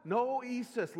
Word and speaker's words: noesis: The N stands for noesis. Yeah, huh? What? Noesis. Let me spell it for you. N-O-E-S-I-S.